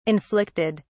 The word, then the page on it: inflicted: British English